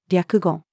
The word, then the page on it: 略語: female